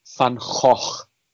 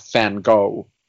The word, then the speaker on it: Van Gogh: It's Gouda and it's Van Gogh. And not Van Gogh.